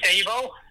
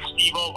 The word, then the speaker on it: Stevo: It's Stevo. It's spelled S-T-E-V-O. It's Serbian, so it always kind of looks like Stevo, but there is no second E at the end of it, so it's just Stevo.